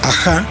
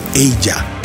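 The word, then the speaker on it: Aja: El disco en cuestión era el Ajá de Stilidan. Y digo, ajá, porque así dice la portada. Ya con muchos años después, sabes que se llama Ella, pero se escribe A-J-A y aparte con acento en la última ajá.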